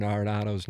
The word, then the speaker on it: Arenado: For the last couple of years, I have been mispronouncing Nolan Arenado's name. It's apparently Arenado, and I was in the press conference in which he said it.